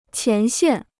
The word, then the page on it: 前线: qián xiàn